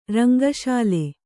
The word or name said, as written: ರಂಗಶಾಲೆ